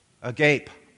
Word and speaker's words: agape: How do we pronounce that in English? Agape.